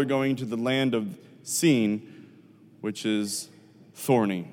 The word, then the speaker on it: Sin: I know it looks like sin in your Bible, but it's pronounced Sin.